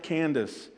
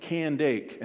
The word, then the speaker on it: Candace: It's not Candace. It's pronounced Candake, and that wasn't her name.